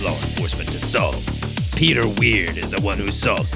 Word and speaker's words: Wyrd: It's a weird one, Joe. I'm going to warn you. It's called Weird, but it's spelled weird. It's spelled W-Y-R-D.